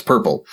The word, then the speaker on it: PRPL: The core library is named libpurple, which is a derivative of, we used to abbreviate protocol plugins as PRPL, that people would pronounce purple.